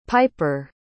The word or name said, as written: pyper